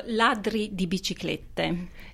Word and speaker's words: Ladri di Biciclette: I was only familiar up until recently with his directing, and particularly with his most prominent and well-known film, Bicycle Thieves, which I'll let you pronounce the Italian because I think I don't want to ruin it again. – So, Ladri di Biciclette. That's the Italian title, yes.